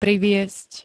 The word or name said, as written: priviesť